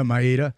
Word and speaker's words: Maeda: They went out and they signed a veteran pitcher in Kenda Maeda, which I always mispronounce his last name. – Maeda, who is a very experienced pitcher.